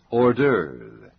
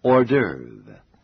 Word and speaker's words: hors d'oeuvre: 这种小点心有一个特殊的名称，那就是 hors d'oeuvre. It is spelled H-O-R-S and D apostrophe O-E-U-V-R-E. It's pronounced hors d'oeuvre.